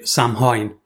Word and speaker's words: Samhain: But Samhain is spelt very differently to the way it's pronounced. So it's spelt Samhain. So Samhain. Samhain was kind of to celebrate the end of harvest.